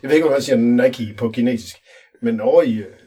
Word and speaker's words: Nike: Altså mine børn, de kan jo godt komme op og slås om, hvorvidt det udtales Nike eller Nike. Uh, det er Nike.